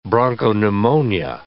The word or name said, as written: bronchopneumonia